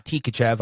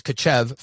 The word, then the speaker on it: Tkachev: It's not Tkachev. I know that I've been saying it that way. I've been saying it incorrectly. So it's Kachev for now.